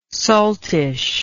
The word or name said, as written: saltish